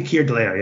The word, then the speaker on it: Keir Dullea: Keir Dullea has always, and I'm going to say Keir Dullea. Do you pronounce it the same way or do you pronounce it differently? Because I've never really. – I say Keir Dullea, yeah.